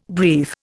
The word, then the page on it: brief: Indian